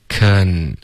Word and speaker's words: can: OK，这个 can 开头嘴巴要张开，k 舌尖在顶着上颚，can 而不是 king king，这是一个非常中式的一个发音。 OK，嘴巴要张开 can。